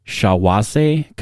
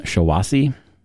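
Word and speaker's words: Shiawassee: Shawase County in Michigan. – Shawassee.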